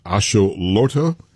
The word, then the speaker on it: axolotl: It's the, for the World Cup, it is a salamander, and it's pronounced axolotl. And it's the, yeah. I guess you would call it the mascot for the World Cup. But it has caused a bit of a problem. What is the controversy? – Yeah. Well, your pronunciation was kind of spot on.